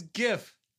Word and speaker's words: GIF: It's GIF. Anyways, go ahead. – That's wild. That is hashtag wild. – GIF. – That's really how you say it.